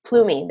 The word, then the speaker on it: Plumlee: Plumlee, how do you say his name? He's a John Rhys Plumlee. There you go.